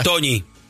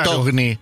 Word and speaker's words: Togni: Toñi. – No. Y él dice, no, mi apellido se pronuncia Togni.